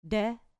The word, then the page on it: de: grec